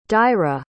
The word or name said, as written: daire